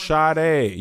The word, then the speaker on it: Sade: Yes, that's how it's pronounced. – There's no H in her name. – But it's pronounced Sade.